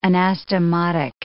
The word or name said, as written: anastomotic